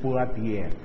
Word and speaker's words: Poitiers: Se escreve Poitiers, mas se pronuncia em francês Poitiers.